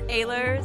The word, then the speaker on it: Ehlers: It's Ehlers.